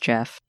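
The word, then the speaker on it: Gef: Also, I will say it is pronounced Jeff.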